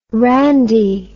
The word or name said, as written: randie